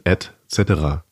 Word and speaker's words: et cetera: Ausgesprochen, et cetera.